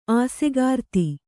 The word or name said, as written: ಆಸೆಗಾರ್ತಿ